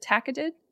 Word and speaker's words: tacketed: Is it tacketed? – Yes, that's perfect.